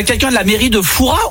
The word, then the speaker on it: Fouras: Eh bien écoutez, on dit Fouras. Ne prononce pas le S. – Et donc on dit bien Père Fouras.